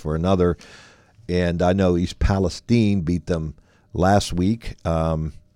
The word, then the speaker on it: East Palestine: Shadyside looked very strong in going up to East Palestine, is how they say it up there, not East Palestine. – They say Palestine.